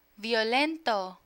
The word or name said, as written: violento